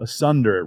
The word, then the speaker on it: asunder: Asunder. It rhymes with thunder.